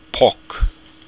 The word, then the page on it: poc: Irish